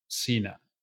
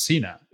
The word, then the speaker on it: Cena: Do you know that John Cena's name, like surname, Cena, it's actually an Italian word? – Oh, yeah? Do tell. – Yeah. And it's not pronounced Cena. It's pronounced Cena, which means dinner.